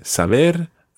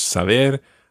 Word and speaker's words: saber: Yeah, saber and conocer. All the infinitives always are stressed on that last syllable. So, mm, so it's saber, conocer.